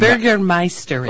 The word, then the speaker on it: Bürgermeister: Bürgermeister. – Oh, Bürgermeister. – In. Bürgermeisterin, meaning female. – Bürgermeisterin. – Yeah, right. – Bürgermeisterin. – Bürgermeister is male. – The gates, Bürgermeisterin.